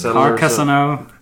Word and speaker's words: Carcassonne: Carcassonne. – Carcassonne.